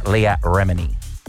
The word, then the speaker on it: Leah Remini: And there's two newcomers, Tracy Tudor, formerly from Million Dollar Listing, and then we've debated how to pronounce this a long time, actress Leah Remini. – Yes, I believe you've said that correctly.